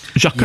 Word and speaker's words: Xhaka: Is that how you pronounce it, Laith? Xhaka. – Yes.